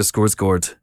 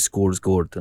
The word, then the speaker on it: Skarsgård: Alexander Skarsgård.